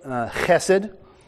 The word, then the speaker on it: chesed: Sometimes they spell it C-H-E-S-E-D, They're trying to get that ch sound, that ch, but we don't have a ch in English. And so you'll see it spelled H-E-S-E-D, and that's how I am going to spell it throughout the messages. And I'm going to pronounce it Hesed, because it's a lot easier to say than Chesed.